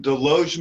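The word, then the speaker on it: Desloge: You grew up in Deloge, Missouri, correct? – Yes, and you get a point for pronouncing it correctly. There are people who want to enunciate the S in there.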